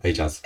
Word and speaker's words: Ajaz: Ajaz.